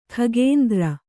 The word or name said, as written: ಕಕುಂದರ